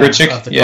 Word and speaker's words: Grichuk: Randall Grichuk, I believe is how you pronounce his last name. – Yeah, Grichuk, Cardinals.